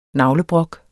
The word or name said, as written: navlebrok